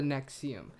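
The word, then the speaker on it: NXIVM: It's spelled N-X-I-V-M. I-V-M. And that's the Nexium.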